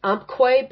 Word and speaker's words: Umpqua: The bank that acquired them, I think it's pronounced Umpqua Bank, and that's U-M-P-Q-U-A.